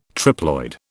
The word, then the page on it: triploid: British English